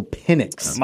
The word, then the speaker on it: Penix: Michael Penix. – Michael Penix. Let's be very clear about the way his last name is pronounced.